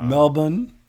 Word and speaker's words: Melbourne: Melbourne. That's how you say it, Melbourne.